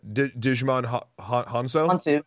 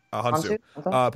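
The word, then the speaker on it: Djimon Hounsou: Digimon Hanzo? – Hansu.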